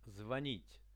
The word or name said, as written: zvonit